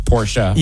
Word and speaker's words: Porsche: Porsche is not Porsche. It's Porsche.